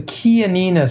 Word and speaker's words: Chianina: That's actually pronounced the Chianina steer.